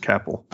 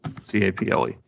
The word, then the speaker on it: Caple: Uh, I think it's actually pronounced Caple, C A P L E, Caple.